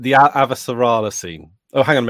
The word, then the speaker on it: Avasarala: Okay, so I think I will not like this for the same reason that you kind of like it, because Avasarala... Am I saying that right? – Yes. Yeah, you're good.